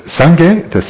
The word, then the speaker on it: Sangye: So Sangye, this word is comprised of two syllables. Thank you. Two syllables. The first being Sang, the second Gye.